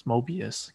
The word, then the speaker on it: Mobius: and then like later on, I guess he's trying to explain something to Morbius. Mobius, I keep saying that wrong.